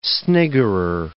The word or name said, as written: sniggerer